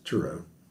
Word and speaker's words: Turow: I read Scott Turow's book. He actually pronounced it Turow, but everybody says Turow.